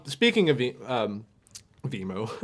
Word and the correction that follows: Zemo: Vimo! Zemo! – We've said this name wrong so many times.